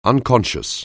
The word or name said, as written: unconscious